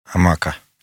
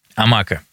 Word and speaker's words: Амака: Я все время ставлю не так ударение. Амака или Амака? – Амака. – Амака. Хорошо. Теперь вопрос уже непосредственно.